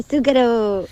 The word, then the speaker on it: Zucchero: Baila morena de Zucchero. – Eso es. Madre mía, cómo lo has pronunciado de bien, ¿eh? Iba a decir Zucchero.